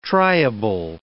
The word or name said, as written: triable